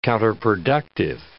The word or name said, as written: counterproductive